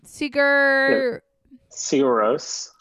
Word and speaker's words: Sigur Rós: Okay, and then next you have, I'm gonna butcher this, Sigur Rós. I don't know. – Sigur Rós. – That's it.